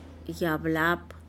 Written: yablap'h

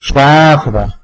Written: xtadba

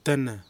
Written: Tanna

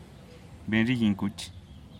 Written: benrigingkuch

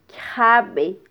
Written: kyjabe

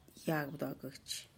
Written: yagbduagutx

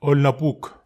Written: ol napuk

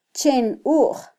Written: chenuj

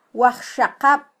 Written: wajxaqab

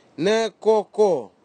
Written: nikoko

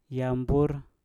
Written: yam bur

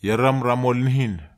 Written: ieram ramol nihin

